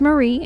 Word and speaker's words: Mare: It's pronounced Marie, and you will see on the episode, it looks like it's spelled Mare, M-A-R-E, but she pronounces it Marie.